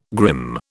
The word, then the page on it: grim: British English